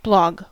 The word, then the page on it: blog: American English